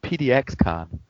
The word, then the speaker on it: PDXCon: No, we say PDXCon. – oh. Um, which is very yeah. That's how you say it.